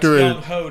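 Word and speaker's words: Younghoe: Young Wei Koo. – Yeah. After that's Young Ho to you, bro.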